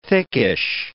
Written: thickish

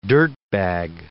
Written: dirtbag

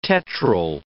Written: tetryl

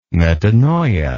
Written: metanoia

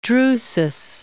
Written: Drusus

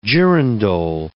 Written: girandole

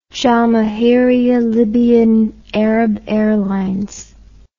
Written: jamahiriya libyan arab airlines

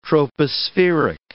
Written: tropospheric

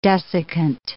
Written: desiccant

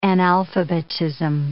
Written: analphabetism

